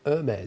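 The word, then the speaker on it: Hermès: And then Hermes. – It's actually Hermes. – Hermes. – The H is silent.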